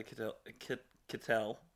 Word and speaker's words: Kittel: You know, because he's second to Kittel in the green jersey. – Rhymes with Skittle.